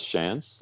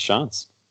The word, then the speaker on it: Shantz: Yeah, most people pronounce it as Shantz, like just if you read my name, but it's pronounced Shantz.